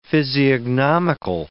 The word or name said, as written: physiognomical